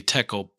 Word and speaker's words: tekel: Mina, and then tekel rhymes with shekel, and then parson.